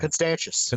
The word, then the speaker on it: Constantius: And then Constantius, I don't know how to pronounce his name quite right. – Constantius. – Constantius. Yeah.